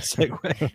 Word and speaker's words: segue: I feel like that segue. So the word segue, the way it's spelled, S-E-G-U-E, is such a mind F. It's like, who would pronounce that?